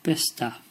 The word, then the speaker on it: Bicester: B-I-C-E-S-T-E-R. Did you say Bicester or Bicester? Then... The correct answer is Bicester.